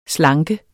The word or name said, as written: SLANKER